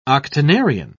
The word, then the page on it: octonarian: British English